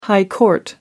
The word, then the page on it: high court: American English